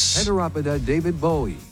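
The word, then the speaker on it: Heteropoda davidbowie: So AI, how do we say this one, please? – Heteropoda David Bowie.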